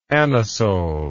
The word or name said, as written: anisole